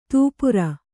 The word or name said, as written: ತೂಪುರ